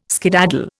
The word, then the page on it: skedaddle: Indian